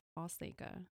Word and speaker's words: Fonseca: In Portugal, there's no N pronounced in it, so it's Fonseca.